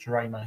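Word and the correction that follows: Drameh: So it's Cody Drama.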